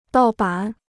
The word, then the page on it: 盗版: dào bǎn